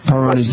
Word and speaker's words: Peyronie's: I was diagnosed with a condition called Peyronie's, and okay. I don't know if I'm pronouncing it right. – You are. Peyronie's disease.